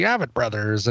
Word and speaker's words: Avett Brothers: And I think it was somebody corrected me once when I played them on Coverville saying, no, Brian, you're pronouncing it wrong. It's the Avett Brothers.